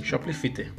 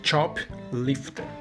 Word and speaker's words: Choplifter: Mas é que tá, não é, não é assim que se pronuncia porque a palavra não é essa. É, é Choplifter. Porque são duas palavras no caso, né? Chop é uma palavra, eu não sei por que, que é essa palavra é assim, mas chop é uma palavra para helicóptero.